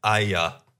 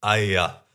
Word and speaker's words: Aya: Nice little funny detail I noticed, that even though the character's name is Earwig, that's the main little girl's name, if you listen to the trailer, it's pronounced Aya, which totally sounds like Earwig. Absolutely. Aya.